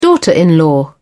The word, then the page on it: daughter-in-law: British English